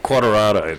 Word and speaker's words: Quartararo: Quartararo, that's it.